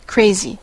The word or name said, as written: crazy